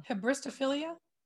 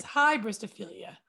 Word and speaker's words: hybristophilia: Hebristophilia. – All right, we got it now. You ready? – It's hybristophilia.